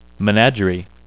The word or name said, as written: menagerie